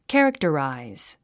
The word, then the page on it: characterize: kǽriktəràiz; キャラクタラァイズ